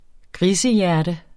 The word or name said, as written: grisehjerte